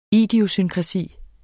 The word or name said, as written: idiosynkrasi